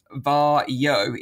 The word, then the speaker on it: Varjo: Uh, it's actually pronounced VARJO, even though it's spelled V A R J O.